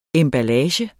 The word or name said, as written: emballage